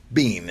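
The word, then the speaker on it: biyn: And this word where it says all those of understanding, that word understanding is a Hebrew word, and it sounds like the word bean. It's B-I-Y-N, bean.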